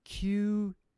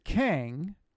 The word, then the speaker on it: QENG: It has Q-E-N-G on it. Do you all know where that's from? – No, please tell us. – Okay, that's you pronounce it Kang, or you can pronounce it Q-Eng.